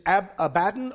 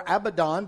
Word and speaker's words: Abaddon: The Hebrew name is Abaddon or Abaddon, depending upon how you want to pronounce it.